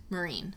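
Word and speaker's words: Meereen: And it's the site of where Game of Thrones was filmed, the city of Mirin. – Marine. – Mm-hmm. I always mispronounce that. Marine. Anyway, you feel like Khaleesi when you walk around the Klis Fortress, for sure.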